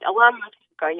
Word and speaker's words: Alona Matchenko: But Alona Matchenko, I hope I didn't slaughter that. Alona, how are you today? – You said it right. Alona Matchenko, yes.